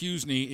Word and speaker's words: Hysni: It is pronounced Husney. It is not spelled like that. It is H-Y-S-N-I, and they said pronounce it Husney.